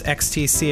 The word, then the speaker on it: XTC: Yeah, per the documentary, it's pronounced XTC and not ecstasy.